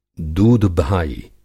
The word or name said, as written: दूधभाई